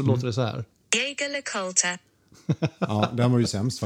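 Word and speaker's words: Jaeger-LeCoultre: Och om man har lite amerikanskt uttal mm. så låter det så här. Jäger LaCoultra. Ja, oh, herregud. Pinsamt. Eller hur? Jaeger LeCoultre. Exakt.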